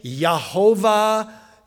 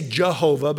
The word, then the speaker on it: Yehovah: So Yahweh, Yahweh, Yehovah, you know, we say Jehovah, but they wouldn't have had a J sound, you know, pronounced like that.